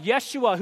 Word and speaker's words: Yeshua: Jesus, whose name, it would have been pronounced Yeshua, whose name means the Lord is salvation.